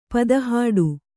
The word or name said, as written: ಪದಹಾಡು